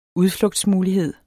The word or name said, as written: udflugtsmulighed